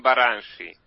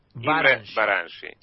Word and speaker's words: Baranyi: Mi nombre, eh, ustedes se pronuncian Baranshi. Baranshi.